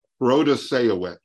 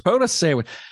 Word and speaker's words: Protasiewicz: Can you pronounce Janet's last name, Protasiewicz? Did I get that right? – Protasiewicz. – Protasiewicz. I think it is definitely one of the more challenging last names,